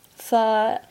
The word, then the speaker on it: phở: Phở, as well, is a traditional Vietnamese food, spelt Pho with an accent on. Don't pronounce it pho because in Vietnamese that means prostitute. It's pronounced phở, like a fur coat.